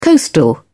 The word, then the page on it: coastal: British English